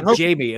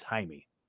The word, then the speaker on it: Jaime: It looks like Jamie. It looks like Jamie, but it's Jaime is how you pronounce it.